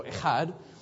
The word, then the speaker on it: Echad: Echad is the fun one, right? So everybody wants to make that sound in Hebrew where you're choking on a pancake. So Echad.